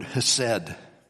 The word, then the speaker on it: chesed: He knew that the love, because your love, that's the Hebrew word chesed. I can't even say it right, because you got to kind of hack a loogie to get it. Chesed. That's how it goes.